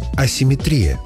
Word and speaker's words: асимметрия: Асимметрия. Слово, в котором ударение часто ставят по аналогии. Есть симметрия и получается асимметрия, но литературная норма иная. Асимметрия с ударением на «и».